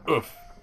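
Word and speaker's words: œuf: I think it's pronounced oof. It's O-O-E-U-F.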